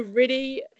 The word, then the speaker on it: Reddy: so Ready, Hannah Ready, so Ready is R-E-D-D-Y. – That's awesome. And Hannah's with an H at the end, so H-A-N-N-A-H.